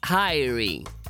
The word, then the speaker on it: Hirie: Adesso invece ci dedichiamo al disco di questa settimana, che è il nuovo album intitolato Phases di Hyrie. Hyrie, che si scrive H-I-R-I-E,